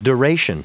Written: duration

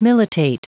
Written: militate

